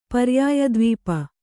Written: ಪರ್ಯಾಯದ್ವೀಪ

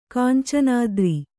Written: ಕಾಂಚನಾದ್ರಿ

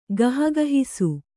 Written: ಗಹಗಹಿಸು

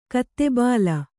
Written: ಗತಬಲ

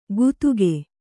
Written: ಗುತುಗೆ

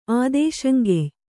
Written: ಆದೇಶಂಗೆಯ್

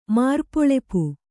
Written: ಮಾರ್ಪೊಳಪು

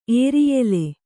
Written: ಏರಿಯೆಲೆ

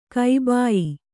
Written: ಕಬಾಯಿ

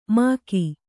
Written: ಮಕಿ

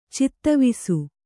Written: ಚಿತ್ತವಿಸು

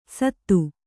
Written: ಸತ್ತು

